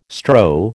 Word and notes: strow: American English

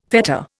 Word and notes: fetta: Indian

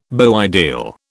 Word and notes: beau ideal: British English

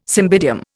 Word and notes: cymbidium: Indian

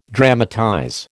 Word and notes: dramatize: American English

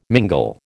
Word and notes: mingle: American English

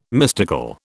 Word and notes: mystical: British English

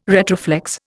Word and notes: retroflex: Indian

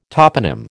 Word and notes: toponym: American English

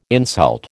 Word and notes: insult: American English

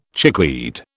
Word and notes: chickweed: British English

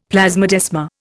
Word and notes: plasmodesma: Indian

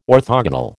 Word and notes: orthogonal: American English

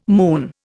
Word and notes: moon: Indian

